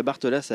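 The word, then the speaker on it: Barthelasse: Si c'est bien ça, sur le tract, en tout cas, c'est écrit comme ça, je le dis phonétiquement, et ça se passe sur l'île de la Bartelasse à Avignon. Je le dis bien, et ben ça, c'est formidable.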